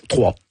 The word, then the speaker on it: Troyes: Vous commencez à jouer, bon, vous êtes né à Troyes, on dit Troyes en France, comment on prononce Troyes. – Troyes, ben voilà, ça s'écrit YES en France.